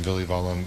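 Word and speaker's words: Villivalam: And my name is spelled Ram, R-A-M, as in Mary. First name, last name, Villivallum, V as in Victor, I-L-L-I, V as in Victor, A-L-A-M, as in Mary.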